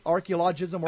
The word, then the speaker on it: archaeologism: Archaeologism? – I think the accent's on that first O, archaeologism.